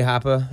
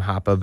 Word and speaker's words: Hapa: I'm at DJ Hapa, H-A-P-A on everything. There's some other guys that are out there. Shout out to them that pronounce their name Hapa, but maybe spell it differently. – Okay. – So make sure DJ Hapa, H-A-P-A.